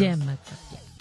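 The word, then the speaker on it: demagoguery: The pronunciation, like the emphasis is on the D. So that's oh, it is. – Demagoguri.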